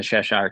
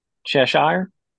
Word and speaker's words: Cheshire: pepper jack stuffed pretzel from the Cheshire Cafe. – So, on the topic of snacks, my favorite snacks were probably the Chess. How do you say it? – Cheshire. Cheshire.